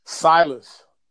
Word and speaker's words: Silas: You can find my Facebook at SILAS. HARRIS. – What is that? – Silas.